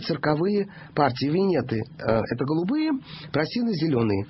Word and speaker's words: Прасины: Там были Просины и Венеты. Некоторые энциклопедии дают ударение «Прасины и Венеты». Я не могу это принять и переделаться. Все мои учителя говорили про «Просины и Венеты».